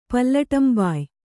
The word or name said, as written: ಪಲ್ಲಟಂಬಾಯ್